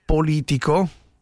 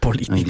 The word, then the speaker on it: Politico: La rivista, o meglio il sito Politico... – Politico. Diciamo così, non so se dobbiamo. Devo sempre insegnarti la pronuncia delle cose.